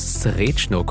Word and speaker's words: Srečno: Servus, Srečno, ciao, Betonung auf Srečno.